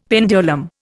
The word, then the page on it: pendulum: Indian